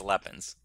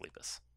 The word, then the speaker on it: Lepus: I think that's Lepins. – No, I think it's French. – I think it's Lepus. – Lepus? – I'm pretty sure it's pronounced Lepus.